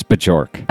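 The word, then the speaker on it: Björk: You have to pronounce the J in there, by the way. – Oh, okay. – It's not Bjork. It's Bjork.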